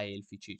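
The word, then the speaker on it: aelfici: Vedete che effettivamente più, qui appunto elfici è scritto a elfici, cioè appunto elfici, non elves. – Ovviamente con la A dietro, ma comunque si legge E, come tantissime cose. Come i demons, come i demoni sono scritti A-E, ma sempre demoni diciamo.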